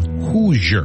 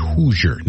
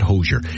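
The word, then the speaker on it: Hozier: Let me give you my H-O-Z-I-E-E-R, but he says you can call him Hoosier, because that's the way he likes it pronounced. Hoosier, not Hosier.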